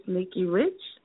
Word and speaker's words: Nikki Rich: And you can check us out at Miss Nikki Rich. And that's spelled at MSNIKKIRICH or at Nikki Rich Show LA.